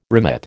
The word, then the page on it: remit: American English